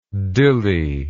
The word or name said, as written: Dili